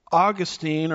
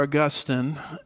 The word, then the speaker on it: Augustine: Augustine, or Augustine, depends on which syllable gets the emphasis, as I like to say, was influenced by Gnosticism.